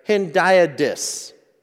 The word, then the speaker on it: hendiadys: It's called a hendiad. And let me see if I can make sure I pronounce this right. Hendiadys.